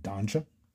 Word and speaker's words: Donja: Wait, Donja? – Donja. – Rhymes with Ganja.